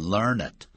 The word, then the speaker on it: learned: 这个 learn 要念成 learned 它是形容词是代表博学多闻的如果当动词的时候 learn 加 ed 是过去分词就要念成 learned He has learned many things So he is learned 他学了很多东西所以他很有学问 He has learned.